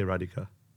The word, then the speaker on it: Radhika: I say Radhika, even though I know it's Radhika with a A-D-H-I-K with an A on top as well.